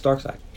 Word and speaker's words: Darkseid: That's Darkseid. It's actually, but it's spelled Darkseid.